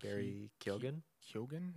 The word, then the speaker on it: Keoghan: Barry Keoghan, maybe? – Yeah.